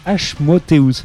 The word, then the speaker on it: Asmodeus: En tout cas, Asmodeus, c'est ça, c'est comme ça que ça se prononce, Raph.